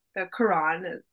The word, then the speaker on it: Quran: Judaism is involved in there, and so is Islam, but I'm not as well versed because, of course, you know, the Quran, or am I saying that right? Yeah.